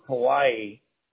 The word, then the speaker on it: Hawaii: Just like Hawaii. You know how you pronounce Hawaii? It's actually Hawaii. The W is pronounced as a V out here.